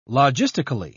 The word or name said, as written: logistically